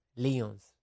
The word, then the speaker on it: Lyons: Nikita Lyons se escribe. León como el equipo de fútbol. Nikita. – Mm. – Nikita. Lyons.